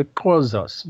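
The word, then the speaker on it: Xhosa: They were Zulus and they were Khoisas. Now, the Khoisas were... Sorry? – Khoisas. I'm trying to pronounce it correctly with the click, Khoisas, right? Because I know they use a click.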